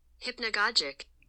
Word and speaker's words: hypnagogic: Hypnagogic. – Oh, you pronounced it correctly.